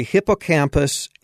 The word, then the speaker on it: hippocampus: You're talking about the hippocampus, and is that how you pronounce it? Hippocampus? – Yes.